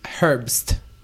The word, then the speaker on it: Herbst: It's Herbst. – How do you spell that? – H-E-R-B-S-T. – Oh, okay. You're saying it perfectly then.